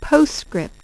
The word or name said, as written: PostScript